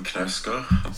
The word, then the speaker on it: Knausgard: Um, Knausgard with some there's some Norwegian pronunciation. So uh, here you go. I'm Karl Ove Knausgard.